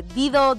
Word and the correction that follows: Dido: Daido, como se pronuncia esta mujer.